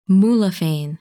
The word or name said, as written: Muliphein